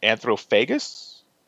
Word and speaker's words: Anthropophagus: I watched, since I'm on an Italian movie kick, I watched hopefully I'm saying this right, Anthropophagus? – Close enough.